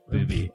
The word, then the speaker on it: Bauby: As Michael would say, the astonishing true life story of Jean-Dominique Bauby, a man who was... – It's pronounced Booby. – A booby? – Okay. – The astonishing true life story of Jean-Dominique Bauby,